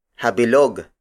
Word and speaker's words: habilog: The next word is habilog. Habilog. Habilog. Habilog is spelled as H-A-B-I-L-O-G.